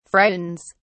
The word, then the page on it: frightens: British English